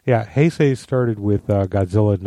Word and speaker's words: Heisei: I thought those were the Hensei? – No, the Heisei. I'm probably mispronouncing it.